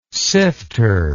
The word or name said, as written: sifter